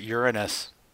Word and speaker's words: Uranus: Out of all the names in the world, they call it Uranus. – Well, I know that some people pronounce it Uranus. Just to maybe take away from that whole anus part of it.